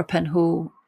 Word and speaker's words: Penhaul: I'm on Instagram, Twitter, the usual, and just for under my name, so at Laura Penhall, which is spelled P-E-N-H-A-U-L.